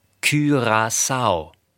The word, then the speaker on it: Curaçao: Curaçao spricht man etwa so aus: Curaçao.